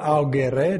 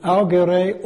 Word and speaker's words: augere: and that comes from the verb augere or augere, depending on what Latin you pronounce. And augere, augment, you've got it in augment, it means to cause something to grow.